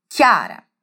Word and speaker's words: Chiara: O meu primeiro nome é pronunciado assim, Chiara, mas ele é escrito com CH, ou seja, C-H-I-A-R-A. No português, essa representação gráfica de CH juntos vai sempre apresentar um som de X.